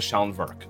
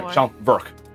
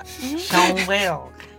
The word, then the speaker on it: Schellenwerk: Schellenwerk. So there are obviously like designations of specific groups. Sure. Sorry, Schellenwerk. I actually pronounced it wrong.